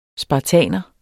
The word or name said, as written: spartaner